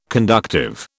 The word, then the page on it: conductive: British English